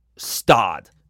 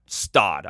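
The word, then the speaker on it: Gstaad: I believe you pronounce this place Stad. It's spelled G-S-T-A-A-D. I'm not great with that pronunciation, but it's a Swiss Open, and it's in a place called, I'm going to guess the G is silent, Stad.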